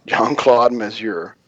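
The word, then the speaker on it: Jean-Claude Mézières: John Claude Mézières I always had to stop to pronounce his name. Jean Claude Mézières as a French writer.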